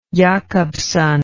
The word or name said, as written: jakobson